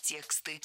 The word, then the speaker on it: тексты: Вчитываться нужно не в текста, а в тексты. Слово «текст» тоже имеет неподвижное ударение на основе, поэтому всегда ударяем первую «е».